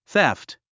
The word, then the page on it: theft: θéft; ゼフト